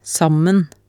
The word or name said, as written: sammen